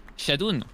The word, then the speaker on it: Shadoune: y se dice Shadun. Así es mi nombre, es Sí, Shadun.